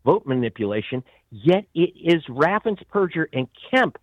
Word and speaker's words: Raffensperger: So does his Secretary of State, whose name is mispronounced as Brad Raffensperger. It's Raffensperger. He is the purge in general of Georgia.